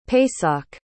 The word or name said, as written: pesach